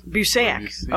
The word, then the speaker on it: Busiek: It's Busiek? – Oh, yeah, Kurt Busiek, yeah. – Busiek, okay.